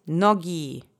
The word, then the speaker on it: nogi: The next word is nogi. This means legs. This is a noun in its plural feminine object form. Nogi, legs plural. Let's say nogi together three times. Accent is on the first syllable.